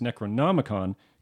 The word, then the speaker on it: Necronomicon: He was actually saying it incorrectly. It's Necronomicon, comes from H.P.